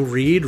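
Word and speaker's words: Reid: I am on Twitter at Joe Reed.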